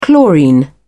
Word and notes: chlorine: British English

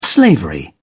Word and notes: slavery: British English

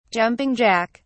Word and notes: jumping jack: British English

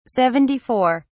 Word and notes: seventy four: British English

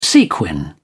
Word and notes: sequin: British English

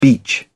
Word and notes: beach: British English

